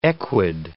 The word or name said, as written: equid